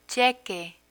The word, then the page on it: cheque: spanish